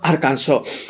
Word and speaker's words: Arkansas: Deberá ser pronunciado en 3 sílabas, con la última se muda, la A en cada sílaba con el sonido de la pronunciación italiana y la acepto en la primera y última sílaba. Espera. Lo que termina siendo Arkansas.